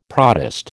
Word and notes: protist: American English

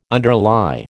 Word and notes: underlie: American English

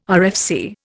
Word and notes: RFC: Indian

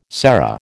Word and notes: serra: American English

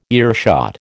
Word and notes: earshot: American English

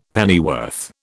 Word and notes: pennyworth: British English

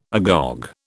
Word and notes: agog: British English

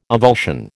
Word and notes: avulsion: American English

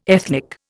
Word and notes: ethnic: Indian

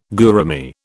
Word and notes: gourami: British English

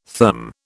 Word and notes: therm: British English

